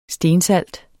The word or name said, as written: stensalt